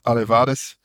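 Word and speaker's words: Aleuadai: Die was net als vele andere kunstenaars en wetenschappers, werd hij een beetje ondersteund door de Aluwadai-clan, uitgesproken als Alevades, de oude heersers over Larissa.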